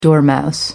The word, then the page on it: dormouse: American English